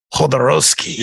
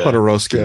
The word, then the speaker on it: Jodorowsky: What is the correct pronunciation? – Alejandro Jodorowsky. – Jodorowsky. – Yeah. – Jodorowsky. – Yeah, the J is like an H.